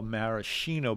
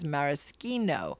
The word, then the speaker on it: maraschino: I say, and dictionaries and speakers of Italian do too, that the preferred pronunciation is maraschino. I learned that from the cartoon character Snagglepuss, who was the first person I ever heard pronounce the word. My wife says it should be maraschino because she claims that's how most people say it.